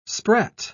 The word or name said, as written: sprat